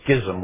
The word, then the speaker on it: schism: I've introduced a new word there called a schism, or some people will pronounce that schism. Can't do that. I'm going to pronounce that a schism with a ch with a hard k sound.